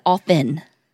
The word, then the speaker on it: often: No, I didn't say often because you're a serial killer that pronounces the T. I said often.